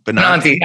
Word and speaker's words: Benanti: And the director of the advertising focus group is Laura Benanti. I think I'm saying that right. – Benanti, I got you.